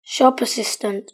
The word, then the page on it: shop assistant: British English